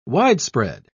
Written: widespread

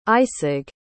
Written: Eisig

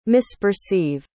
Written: misperceive

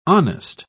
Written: honest

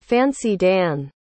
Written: fancy-dan

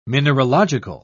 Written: mineralogical

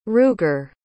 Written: ruger

